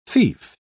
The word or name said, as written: fief